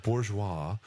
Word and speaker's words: Bourgeois: Is that how people are pronouncing it? – Yeah, Bourgeois. – I've heard. – Bourgeois. – Okay, so Bourgeois is going to play center. – He took on the French pronunciation.